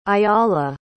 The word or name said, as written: ayala